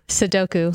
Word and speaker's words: Sudoku: Sudoku.